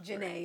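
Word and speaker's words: Jené: My song this week is from, I don't know if I'm saying this right, Mikala Janae. It's M-I-K-H-A-L-A. Mikala J-E-N-E with the apostrophe over. – Janae, yeah.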